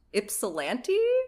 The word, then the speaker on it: Ypsilanti: It's apparently pronounced Ypsilanti? – Oh, there you go. – Spelled with a Y, so you knew I was already, like, my brain was already panicking from that, so.